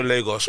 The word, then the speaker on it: Lagos: Lagos.